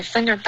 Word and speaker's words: figure: I think I figured out a much faster route. – Figured，你加了一个后鼻音了，变成 finger 了，然后就变成手指了。 Figure 是一个 e 的音，没有鼻音。